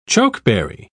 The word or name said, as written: chokeberry